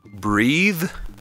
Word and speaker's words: breathe: Però quando ce l'hai finale, in questo caso breathe, abbiamo un suono sonoro alla fine, the, quindi le corde vocali si muovono ed è il verbo respirare, to breathe.